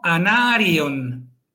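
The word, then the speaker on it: Anárion: Y lo mismo, anarion, eldarion. Son tienen en este caso cuatro sílabas porque la I y la O están separadas.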